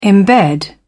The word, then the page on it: imbed: British English